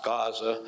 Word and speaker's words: Gaza: now it's pronounced Gaza, which is desert.